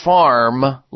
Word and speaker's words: Pharm: So make sure if you're using a turmeric, you're using Future Farm liquid turmeric. And farm is spelled P-H-A-R-M.